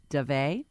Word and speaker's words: Daveigh: I'm going to say I think this girl's name is pronounced Davey. – Okay. – Possibly Davey. – Okay. – D-A-V-E-I-G-H.